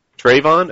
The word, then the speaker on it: Trayvon: Tavon Martin? Did I pronounce his name right? I learned all about it by readings. Trayvon? Okay. – That's right.